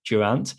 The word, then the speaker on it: Durant: Jorunt or Durant? One of the two. – Durant. It's going to be Durant. – That sounds better.